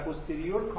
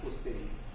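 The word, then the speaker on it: posterior: و اینا رو اگه بگه انتریور و پوستریور کاملا غلط، تلفظ انتریر و پوستریر، قدامی و خلفی.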